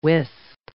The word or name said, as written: wisp